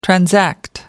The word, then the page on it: transact: American English